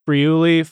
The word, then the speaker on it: Friuli: I wanted to, you're here to talk about your book, Friuli Food and Wine.